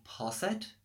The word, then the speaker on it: Posset: I don't know if I'm pronouncing it right, it was called Posset, like, P-O-S-S-E-T.